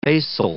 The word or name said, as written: basal